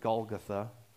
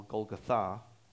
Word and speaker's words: Golgotha: Or sometimes they pronounce it Golgotha. The actual pronunciation puts the emphasis on the last syllable, Golgotha.